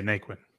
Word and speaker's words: Naquin: Yeah, Naquin.